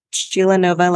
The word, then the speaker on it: Sheilanova: Sheilanova is my first name. It's all one word. Sheilanova, like supernova.